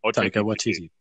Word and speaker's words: Taika Waititi: Taika Watiti. – Dafür haben wir dich. Taika Verb. Taika Waititi.